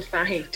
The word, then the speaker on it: Fahey: Rachel, have I said your name right? Your surname? – Rachel Fahey. Don't worry, I'm Irish.